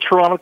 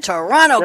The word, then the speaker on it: Toronto: You don't ever say the second T, so it's Toronto.